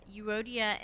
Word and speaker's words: Euodia: And so we encounter these two women, Euodia and Syntyche. That's how you pronounce it.